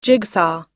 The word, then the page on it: jigsaw: American English